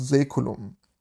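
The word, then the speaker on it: Saeculum: Aber viele sprechen es immer noch Säkulum aus. Aber anscheinend wird AE im Lateinischen Ä ausgesprochen, also Säkulum.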